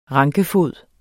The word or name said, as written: rankefod